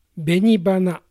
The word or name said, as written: Benibana